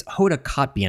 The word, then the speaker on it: Hoda Kotb: It's Hoda Kotb, and I always mispronounce her name, but we're not talking that she's still going to drink Chardonnay in the 10 o'clock hour.